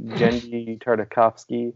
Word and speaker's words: Genndy Tartakovsky: And then our bully, who we can never say his name right, Genndy Tartakovsky. I think I did it. – You did it.